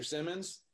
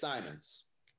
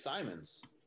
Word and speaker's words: Simons: It's just something where somebody will say, Mr. Simmons, it's Simons. It's Simons.